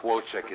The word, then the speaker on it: Wojcik: It's close enough. Wojcik is the correct pronunciation.